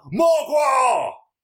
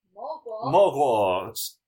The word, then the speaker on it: Morgawr: I think it's, you just hear the yell, Morgwar! – Morgwar.